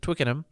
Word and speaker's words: Twickenham: Twickenham.